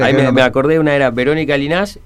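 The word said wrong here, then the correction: Llinás: ¿Lo dije bien? – No. Verónica. No, sí, se escribe así, pero se pronuncia Ginás.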